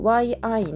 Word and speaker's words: 一: これは、あの、関数字 1 はですね、もともと E という YI の第一声、高く平たく発音する音というのが本来の発音なんですが、この漢字はですね、えーと、後に何が来るかによって成長が変化する単語になります。